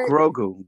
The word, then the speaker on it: Grogu: The child, Morgu, am I saying his name right? – What'd you call him? – Is it Morgu, right? – Grogu. – Grogu. I say it with that hard M.